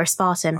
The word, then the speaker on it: aspartame: That aspartame story recently really bothered me that, you know, aspartame is terrible, or aspartame. I don't know how to pronounce it, actually. – Yes, aspartame, as in Diet Coke.